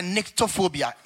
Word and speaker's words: nyctophobia: And that is called a nyctophobia. N-Y-N-C. Let me spell it for you if you want to read more. N-Y-C-T-O and then phobia.